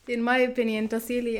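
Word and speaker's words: Tassili: Tahsil in Ajir. – Yeah. – I fucked up the pronunciation. How do you say it? – Tahsili, Tahsili.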